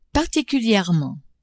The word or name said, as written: particulierement